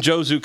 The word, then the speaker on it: Jozu: which is your book from Jozu Comic.